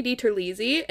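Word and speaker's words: DiTerlizzi: It was based on the Spiderwick Chronicles book series by Tony DiTerlisi, and I did pronounce how to pronounce his last name. Jesus fucking Christ. I did look up how to pronounce his last name, and that's how he says it.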